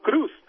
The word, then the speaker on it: Cruz: So we should call him Cruz, because it rhymes with goose.